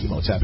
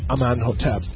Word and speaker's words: Amenhotep: it's not Imhotep, it's Amenhotep. – People mispronounce a strong contender is founder of ancient Egypt's most famous necropolis, Amenhotep I, from 1525 to 1504.